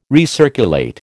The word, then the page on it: recirculate: American English